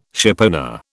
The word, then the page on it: shipowner: British English